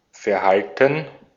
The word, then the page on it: Verhalten: /fɛɐ̯ˈhaltn̩/; /fɛɐ̯ˈhaltən/